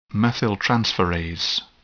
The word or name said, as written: methyltransferase